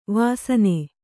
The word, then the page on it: ವಸ್ನ: vasna